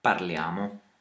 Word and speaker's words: parliamo: Parliamo. – And notice that like in the word parlate, the accent is pushed onto the second part of the word. So it's not parliamo, but parliamo.